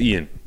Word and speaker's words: Iain: Is it pronounced Ian? It's Ian. – Ian. Oh, okay. Just it's the the Scottish spelling.